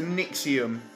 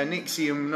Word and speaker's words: NXIVM: So how's it spelled? It's spelled... NXIVM. So NXIVM. – That's their name. That they have posted up. – Right, okay. – But, yeah, it's a Nixium. – Yeah. – Yeah, Nixium, not Nexus.